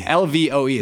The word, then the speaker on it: LVOE: LVOE. It's not love, but it's spelled, okay.